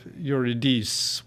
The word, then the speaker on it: Eurydice: and then Amy Mathurin is performing the role of Eurydice, which is the pronunciation in French,